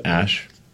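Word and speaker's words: Æ: So, I mean, it's just X, the letter X, and then the AE is like pronounced Ash.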